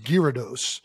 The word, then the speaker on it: Gyarados: I mean, it's got a G-Y, I think, unless we're going like Greek, Gyarados. apparently it's pronounced Gyarados, which I've never seen a G-Y go gear, but you know, whatever.